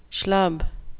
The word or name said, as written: schlub